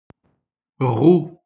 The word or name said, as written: roue